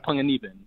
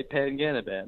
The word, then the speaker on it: Panganiban: So if I'm seeing another, you know, Filipino ethnic type Asian or something, I say it like the real way, like Panganiban. But if I see, you know, like someone that looks like my mom, I say Panganiban.